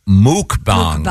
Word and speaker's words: mukbang: It's actually pronounced muk, yeah. – Is it muk? – Mukbang. – Yep. Oh, I thought it was mukbang. – The pronouncer here is M-O-O-K. – Oh, okay. I've always heard muk. The people who do it, I've heard say muk. – It's spelled M-U-K-B-A-N-G.